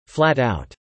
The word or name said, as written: flat-out